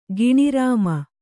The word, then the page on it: ಗಿಣಿರಾಮ: giṇi rāma